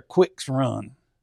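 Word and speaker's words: Quicks Run: It's spelled Quirks, but I think they pronounce it over there Quick's Run.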